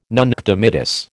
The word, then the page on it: nunc dimittis: American English